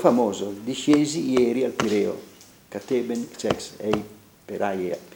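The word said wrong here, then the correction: peiraia (Πειραιᾶ): peiraia, perché l'accento grave sulla ultima peiraia.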